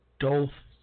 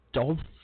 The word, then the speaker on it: Douthat: And Douthat, I think I pronounced that right, D-O-U-T-H-A-T, Douthat. Douthat, how do you even survive years and years and years with a name like that?